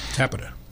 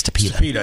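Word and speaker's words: Tapeta: And he is in California when they switched to fake dirt, to PETA, however you pronounce it. – Tapita. Is it Tapita? – I think it's Tapita. – I think you're right. I think I put the accent on the wrong spot.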